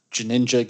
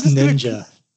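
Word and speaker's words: GNinja: It's Ninja. – It's Ninja. The G is silent.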